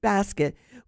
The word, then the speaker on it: bosquet: It's called basket, and it's spelled B-O-S-Q-U-E-T, but it's pronounced basket,